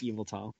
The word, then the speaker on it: Yveltal: Yvetal. Yvetal. Sorry, internet, but it's Yveltal. That's how I pronounce it.